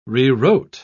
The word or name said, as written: rewrote